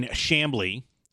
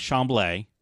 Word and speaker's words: Chamblee: If you're in the Atlanta area and you want to know where it is in Chamblee, which is how it's pronounced, not Chamblee.